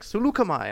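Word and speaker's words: Saleucami: My favorite planet pronunciation is Seleucami, because it's spelled like Selakami, but after watching Clone Wars, they're like Seleucami.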